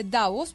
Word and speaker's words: Davos: Fernando Peset dice que se dice Davos porque es sin tilde en español, por lo tanto es clave.